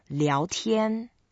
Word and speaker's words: 聊天: So when you put board and chat with the sky together, then you pronounce as 聊天. – 聊天.